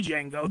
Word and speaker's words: Django: And not the Django. The D is silent, just like in your bedroom.